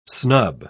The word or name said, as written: snub